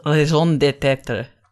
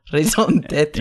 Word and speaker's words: raison d'être: One that may have emerged as a secondary function once the organ lost its original raison d'être. – Yeah, that was a better pronunciation. Yeah, it's still not very good. – No, but it's fine. It's fine for an Englishman.